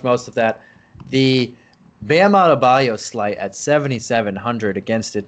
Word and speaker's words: Adebayo: but I always think of him in Born on the Bayou, and that's how I say his name, like Bam Adebayo, just like that.